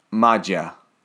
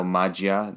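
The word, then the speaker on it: Maggia: That's how I would say it, just thinking, because they're trying to say mafia. – They are. – And the two G's in Italian, like formaggio, right? Ah. So magia, that's, I would think that in my head.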